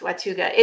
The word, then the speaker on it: Watauga: Um, so if you're in, I think it's Watauga, I want to say. Did I say that right? Watauga. – It's Watauga.